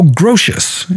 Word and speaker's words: Grotius: The Remonstrant philosopher Hugo Grotius is how the Dutch pronounce it. It's spelled Grotius, G-R-O-T-I-U-S, who died in 1645, wrote that one of his theories would be true, Etsi Deus non deritur, even if God did not exist.